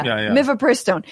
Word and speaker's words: mifepristone: We have misoprostol and mifepristone. I'm pronouncing both of those wrong, I'm sure. – Mifepristone and misoprostol. – Yeah. Mifepristone.